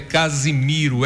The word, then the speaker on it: Casimiro: O nome do poeta é Casimiro, é um S só entre duas vogais, tem um som de Z. Não é Casimiro de Abreu, sim Casimiro de Abreu, ok? Casimiro